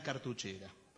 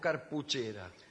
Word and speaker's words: cartuchera: ¿Cómo se dice, cartuchera o carpuchera? – Cartuchera.